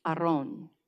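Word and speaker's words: Aron (ארון): The word that is used here, the Hebrew word, is Aron. In English, we would spell it A-R-O-W-N, Aron.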